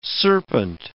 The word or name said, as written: serpent